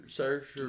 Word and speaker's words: Saoirse: Ah, Saoirse Ronan. Måske. – Saoirse. – Bare sig det så irsk, du kan.